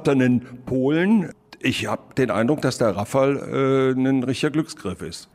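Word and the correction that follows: Rafał: Ich glaube, richtig heißt er Rafau oder wird so ausgesprochen. Aber ich sage auch Rafal mit doppeltem L, äh, weil wir im Rheinland sind.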